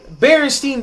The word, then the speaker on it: Berenstain: Apparently, Berenstain Bears is spelled B-E-R-E-N-S-T-A-I-N. – S-T-A-I-N? – Berenstain. – It's Berenstain.